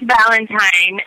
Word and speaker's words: Valentine: No, it's Valentine.